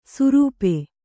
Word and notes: ಸುರೂಪೆ: Kannada

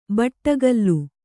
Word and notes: ಬಡಕಲ: baḍakala

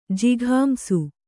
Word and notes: ಜಿಘಾಂಸು: jighāmsu

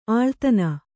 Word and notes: ಆಳ್ತನ: āḷtana

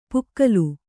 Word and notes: ಪುಕ್ಕಲು: pukkalu

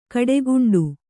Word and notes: ಗಿಡಗಂಟೆ: Kannada

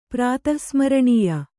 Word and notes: ಪ್ರಾತಃಸ್ಮರಣೀಯ: prātah smaraṇīya

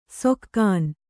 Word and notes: ಸೊಕ್ಕಾನ್: sokkān

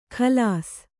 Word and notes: ಖಲಾಸ್: Kannada

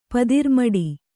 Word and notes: ಪದಿರ್ಮಡಿ: kannada

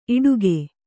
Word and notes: ಇಡುಗೆ: iḍuge

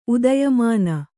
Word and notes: ಉದಯಮಾನ: Kannada

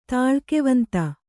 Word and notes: ತಾಳ್ಕೆವಂತ: Kannada

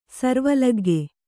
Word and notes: ಸಾರ್ವಲೌಕಿಕ: sārva laukika

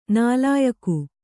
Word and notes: ನಾಲಾಯಖ್: nālāyakh

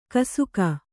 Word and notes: ಗೋಸಗೆ: kannada

